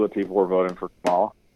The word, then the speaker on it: Kamala: Kamala. It's like Camelot with a llama.